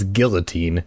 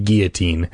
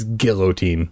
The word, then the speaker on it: guillotine: it is guillotine. And she goes with the classic French pronunciation of guillotine. And though that sounds better, I agree. The proper word in English is guillotine.